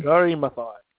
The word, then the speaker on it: Wangari Maathai: I don't know if I'm saying her name right, but it's Wangari Maathai. – Wangari Maathai.